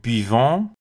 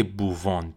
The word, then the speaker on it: buvons: برای همین من میگم نو بیوان و بیوه بیوه بیوان نه که بووان بووه